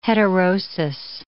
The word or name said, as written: heterosis